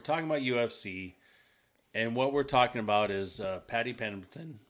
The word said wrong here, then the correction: Paddy Pimblett: right? Did I say it right? – Paddy Pimblett. Yeah, Paddy Pimblett.